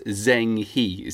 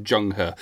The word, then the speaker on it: Zheng He: If you're English, you might read his name as Zheng He, Z-E-N-G-H-E. But in Chinese, it's pronounced Zheng He.